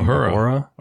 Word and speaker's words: Uhura: Uhura.